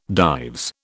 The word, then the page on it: Dives: British English